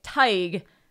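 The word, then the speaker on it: Tadhg: Pronounced. Okay. Tig. Rhyming with tiger without the R. – Great. So Tig Murphy, conversely to the, you know, here's a guy a little disheveled looking.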